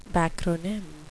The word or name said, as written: backronym